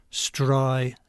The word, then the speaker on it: Streisand: with the second alveolar fricative voiced. It should be unvoiced. Streisand.